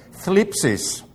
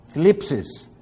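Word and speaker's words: Thlipsis (θλῖψις): Thlipsis. Now, there's a TH and an L and an I and a PSI all together. Okay? Thlipsis.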